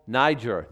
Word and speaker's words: Niger: Simeon, that was called Niger. That's how you pronounce that.